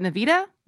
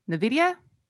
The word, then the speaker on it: Nvidia: Is it Nvidia? Nvidia? – Sure.